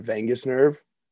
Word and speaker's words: vagus: And one thing I read in that book was that the gut and the brain are connected through the vagus nerve.